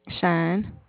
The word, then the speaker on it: Shyne: Shine. – Spell it. – S-H-Y-N-E. – S-H-A. – Y. No, S-H-Y-N-E. – S-H-Y. – N-E. – Uh-huh. Shay? – Shine.